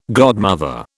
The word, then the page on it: godmother: British English